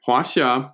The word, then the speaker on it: Huaxia: Okay, pronounced Huaxia. The X is like kind of like a between an S and an SH sound.